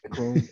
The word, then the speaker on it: Macron: Macron? Am I saying that wrong? – Mark? Sounds right to me. – Macron, M-A-C-R-O-N, yeah.